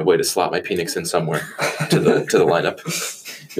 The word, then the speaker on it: Penix: I know it's pronounced Penix, but I'm going to say Penix because I'm immature like that, and it's just funny.